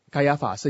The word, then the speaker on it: 该亚法: 而他罢免之后呢，该亚法基本上刚才读错，读成该亚。该亚法本身呢是在主后的第 18 到 36 年。该亚法是主后 18到36年做大祭司的。该亚法跟亚拿之间有关系吗？有。该亚法是亚拿的女婿。